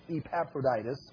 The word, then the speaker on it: Epaphroditus: Epaphroditus. If I say Ep, then I get my syllables mixed up.